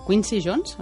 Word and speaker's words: Quincy Jones: Además estás inmerso ahora en un nuevo proyecto con Quincy Jones. Dime si me equivoco el nombre, porque es que yo para la pronunciación... – No, lo has dicho perfectamente.